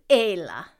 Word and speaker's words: Ayla: I know the pronunciation is Ayla. – Ayla. – Just like A-Y-L-A. And that's because the Fada makes it A. It's a little diphthonged like Ayla, but not enough to be discerning to the ear.